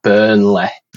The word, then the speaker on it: Burnley: But rather than it being like L-E-Y, we pronounce it L-E-H. So it's like Burnley.